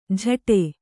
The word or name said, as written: ಜಟೆ